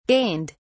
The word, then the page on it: gained: British English